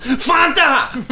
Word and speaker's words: Fanta: And it's actually pronounced Fanta.